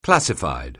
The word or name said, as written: classified